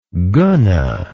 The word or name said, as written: guna